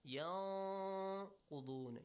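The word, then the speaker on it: يَنقُضُونَ: Yanqudun. You hide the sound but extend it also, yes? – Yes.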